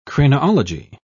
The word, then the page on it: craniology: British English